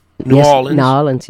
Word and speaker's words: New Orleans: I said it right, New Orleans, yeah.